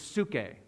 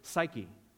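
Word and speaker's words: suke (ψυχή): In the original Greek, this word for life is suke, or we would pronounce it psyche, where we get the word psychology.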